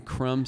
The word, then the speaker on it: Crum: Brian is spelled B-R-I-O-N. Last name Crum, C-R-U-M.